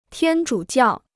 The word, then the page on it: 天主教: tiān zhǔ jiào